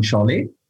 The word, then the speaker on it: Saleh: Saleh ist geschrieben, richtig ausgesprochen heißt es dann Saleh, ist ein türkisches Geschlecht.